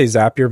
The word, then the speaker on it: Zapier: I say Zapier because they have Zaps.